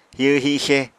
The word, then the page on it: hihw-hixe: Apatani